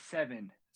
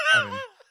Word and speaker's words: Cevin: Is that how you pronounce their name, Kevin, or is it Seven? – Se- Kevin.